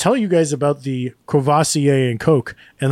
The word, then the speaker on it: Courvoisier: I lose that first R, and I go Courvoisier. – Courvoisier, that's what I know it as, too.